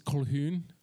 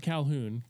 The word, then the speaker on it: Colquhoun: The original name is Colquhoun. – I'm sorry, what? – Colquhoun. – Oh. – Colquhoun. – Calhoun. Okay. – Not Calhoun. Colquhoun. – Calhoun. – That's actually how you pronounce it.